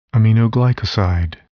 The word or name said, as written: aminoglycoside